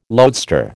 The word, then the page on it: loadstar: American English